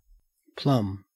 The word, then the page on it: plumb: Australian English